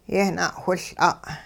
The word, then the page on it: yehna'whiL'a': hupa